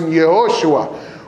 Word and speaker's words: Yehoshua: In the Hebrew, they would have pronounced it Yehoshua, which was Yahweh saves.